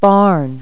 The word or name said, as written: barn